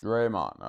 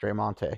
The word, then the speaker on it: Draymond: Draymond, okay. – Because I don't think the T is pronounced. It's not Draymonte or Draymont. So it's because Draymond sounds right.